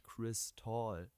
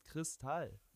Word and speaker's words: Chris Tall: Der heißt nicht Chris Tall. Der heißt Kristall.